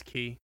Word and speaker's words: ki: Is it ki or chi? I can't remember how it's pronounced in this game. – I believe it's ki. – Yeah, they say ki in this one.